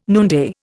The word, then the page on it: noonday: Indian